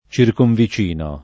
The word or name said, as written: circonvicino